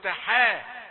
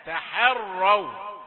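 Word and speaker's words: تحرّوا: خد بالك هتقرأ ازاي، تحروا ولا هترقغ الحاء وتقول تحا، تحروا.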